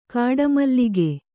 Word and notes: ಗಿಡಮೂಲಿಕೆ: giḍa mūlike

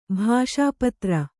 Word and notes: ಭಾಷಾಪತ್ರ: kannada

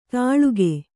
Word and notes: ತಾಳುಗೆ: kannada

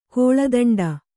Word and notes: ಕಾಳದಂಡ: Kannada

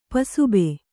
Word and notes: ಪಸುಬೆ: pasube

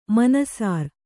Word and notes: ಮನಸರಿ: manasari

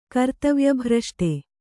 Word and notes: ಕರ್ತವ್ಯಭ್ರಷ್ಟೆ: Kannada

